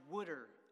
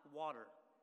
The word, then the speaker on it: water: Or if I didn't know that it's pronounced wooder and not water.